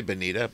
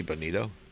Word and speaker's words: bonito: We all, we all say bonita, but it's actually bonito. Okay, yeah, I'm glad you mentioned you, you pronounced it correctly. Everybody says Bonita, but it's not an A, it's an O. It ends with an O.